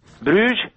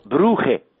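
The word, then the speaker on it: Brugge: Bruj sau Bruhe, cum sună pronunția venită pe gâtlești flamand, e o glorie trecută, dar ce glorie!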